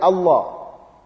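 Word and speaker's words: Allah (الله): Now this, the word of Allah, the word Allah, usually the proper pronunciation of it is full mouth. It must be pronounced full mouth.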